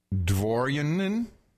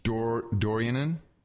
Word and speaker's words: Dworianyn: D-W-O-R-I-A-N-Y-N. – Dvorianin? – I don't think W is usually never pronounced a V. – Dorianin. How about Dorianin? Dorianin. Tim Dorianin. – It could be Dorianin. – I like Dorianin. Sounds good.